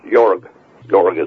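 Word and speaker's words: Jörg: Yeah, well, Haider, of course, Jorg is the way you pronounce that one, Jorg Haider.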